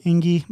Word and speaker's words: Enge: It's Rob Enge. My last name is spelled E-N-G-E.